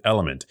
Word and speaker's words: LMNT: But if we were, you can bet your bottom we'd recover from our Turkey Day sweat session with Element. It's spelled LMNT, but pronounced Element.